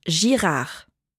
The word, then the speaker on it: Girard: Mein Familienname ist Girard. – Entschuldigung, wie schreibt man das? Buchstabieren Sie das bitte. – G-I-R-A-R-D.